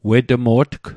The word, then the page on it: wetmo'tg: Mi'gmaq